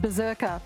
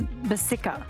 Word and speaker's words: Berserker: And apparently the suburb isn't said Berserker, which is kind of disappointing. I should have asked. It's Bersika. Bersika, I think, is how you say it.